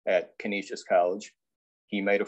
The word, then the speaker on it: Canisius College: Oh, by the way, you did nail the pronunciation of Canisius College. you nailed it.